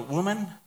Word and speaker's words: woman: Woman, woman.